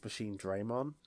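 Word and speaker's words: Machinedramon: But that's not how you pronounce it. It's Machine Draymon.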